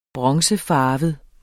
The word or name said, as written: bronzefarvet